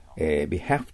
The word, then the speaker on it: behalf: 次の単語は 775 は、はい、 behalf と書かれてますけども、 behalf と読むのが、米国式ですよね。イギリスの方は behalf と読む方もおられるかもしれません。